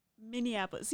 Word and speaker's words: Minneapolis: How's the correct way to say it then? – Minneapolis. See, I think you're making it more difficult when you say Minneapolis. Like. – Oh, Minneapolis. – Yeah, Minneapolis. – Minneapolis. – There it is. See, that's so much easier. – Oh, that's how you. Minneapolis. – Yeah, that's it. – Minneapolis. Oh, I was saying Minneapolis. Cause you're right. If you do try to pronounce it like Indianapolis. Annapolis.